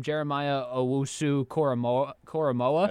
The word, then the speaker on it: Jeremiah Owusu-Koramoah: I also was surprised that, and I'm going to try to pronounce his name, Jeremiah Owusu-Koramoah. – That's right.